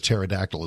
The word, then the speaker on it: pterodactyl: How come you never know when a pterodactyl is going to the bathroom? – I don't know. How can you never know when a pterodactyl is going to the bathroom? – The P is silent.